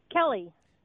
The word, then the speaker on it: Kelly: Kelly. – Kelly, spell it. – K-E-L-L-Y.